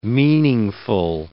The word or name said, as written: meaningful